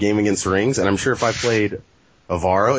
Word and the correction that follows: Avro: Is that how you say it, by the way? Is it Avaro? – No, it's Avro. – Avro. Okay. Well, I'm sure that would have happened, too.